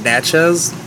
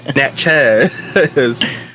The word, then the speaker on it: Natchez: So now, and Nats, almost, I keep pronouncing it as Nazi. It's called Natchez.